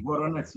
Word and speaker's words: Voronezh: I remember at Veronuz, yeah? Did I pronounce it? – Veronuz, Yeah, which is up near Drew the border of Ukraine. – Voronezh, yeah. Voronezh.